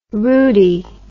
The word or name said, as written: rudie